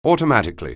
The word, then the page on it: automatically: anglais